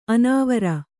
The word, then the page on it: ಅನಾವರ: anāvara